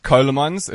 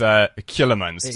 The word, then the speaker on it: Coulemans: It's actually pronounced Coulemans. It's Dutch. – Coulemans. – But because we live in South Africa and we have a bit of Afrikaans influence, it's Coulemans to most people. So actually, I pronounce it Coulemans because it's actually easier for people to get the actual... Otherwise, it's spelled like Cola, Coca-Cola, and...